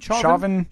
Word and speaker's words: Chauvin: Is it Derek Chauvin? – Chauvin trial.